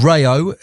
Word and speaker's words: Rayo: It's called Rayo. It's spelled R-A-Y-O.